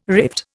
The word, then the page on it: rift: Indian